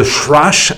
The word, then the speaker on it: thrash: Parce que je vous rappelle, parce que je lis encore un peu trop sur les réseaux sociaux, le thrash, ça s'écrit avec un H entre le T et le R. parce que si tu dis trash, c'est poubelle.